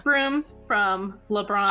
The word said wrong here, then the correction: Lebanon: Did I say that town name correct too? Hello. – No, it's Lebanon.